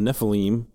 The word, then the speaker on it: Nephilim: It says, the Nephilim, or the Nephilim, depending on how you want to pronounce it, were on the earth in those days.